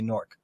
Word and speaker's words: Newark: No, we don't pronounce the A. We don't say Newark. We say Newark.